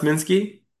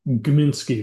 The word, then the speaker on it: Gminsky: Is it pronounced Minsky? – Gminsky. There was a, the G was not silent.